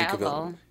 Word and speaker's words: Cavill: Is it Henry Cavill or Cavill? How do you say it? Henry Cavill. – Is it Cavill? The actor? – Yeah.